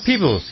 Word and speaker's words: Peebles: Yeah, that was Anne, how do you say, Peebles. – Peebles, yeah, exactly.